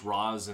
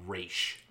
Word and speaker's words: Ra's: I know he's pronounced Roz in the Christopher Nolan trilogy. More often than not, in all other Batman media, Ra's is how it's pronounced.